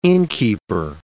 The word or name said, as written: innkeeper